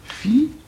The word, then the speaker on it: fiancé: Il peut se faire que dans tel ou tel vers, n'est-ce pas, le poète qui emploie le mot fiancé doit compter ce mot pour trois syllabes. Sans quoi son vers serait faux. Par licence poétique, qu'est-ce qu'il va faire ? Alors, il établira cette prononciation fiancé. C'est-à-dire qu'au lieu d'un iode, il oblige à prononcer un i.